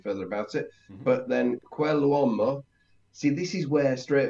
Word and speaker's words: quell'uomo: So quell'uomo, and you should pronounce it perfectly. When we have an apostrophe in Italian, it becomes like a very long word. So quell'uomo.